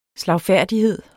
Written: slagfærdighed

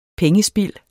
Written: pengespild

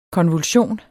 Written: konvulsion

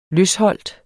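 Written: LØSHOLT

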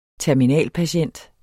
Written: TERMINALPATIENT

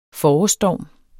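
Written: forårsstorm

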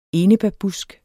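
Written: ENEBÆRBUSK

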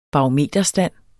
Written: barometerstand

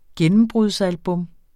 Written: gennembrudsalbum

